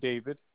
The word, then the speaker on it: David: It's spelled G-O-U-Z-Y. Then my first name, David, D-A-V-I-D.